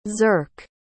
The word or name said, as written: zerk